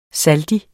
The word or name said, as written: SALDIENE